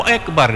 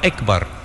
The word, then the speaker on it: akbar: Jangan Allahu Akbar dibaca akbar bukan ekbar.